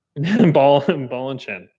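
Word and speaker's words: Ballechin: I believe that's pronounced Balikin, but... – I think it's pronounced Balichin. – Balschin? – Balachin. – That sounds right. That sounds Balachin. Balachin. – Balachin.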